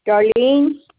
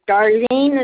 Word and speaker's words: Darlene: Darlene Kee. It's spelled K-E-E. – K-E-E, is that your first name? – That's my last name. Darlene is my first name. D-A-R-L-E-N-E.